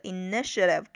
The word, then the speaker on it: initiative: 然后呢，后边还有一个这个 initiative，注意这个词的发音哈，它的重音是这个 initiative 中间的这个 n 上面。